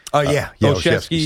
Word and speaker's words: Olszewski: How do you say his name? Olszewski? – Olszewski.